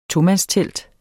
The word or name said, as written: tomandstelt